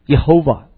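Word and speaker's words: Jehovah: 那么所以呢，犹太人后来为了敬拜的，他们用了另外一个词，就是 Adonai，这个 Lord 这个词里面的三个元音 AOA 呢，把它发到，把它插到那个 YHWH 里面就可以发音了。那就变成中文，其实这个翻译是翻得比英文更准的，就是耶和华 Yehovah，这个就可以发音了。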